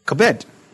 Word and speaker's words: kabed: So it's kabod, but with an E instead of an O. Very interesting. For honor. Kabed. K-A-B-E-D, but pronounced kabed. K-A-W, pronounced K-A-W, stroke B-E-D. K-A-B-E-D, kabed.